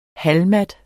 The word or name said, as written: halvmat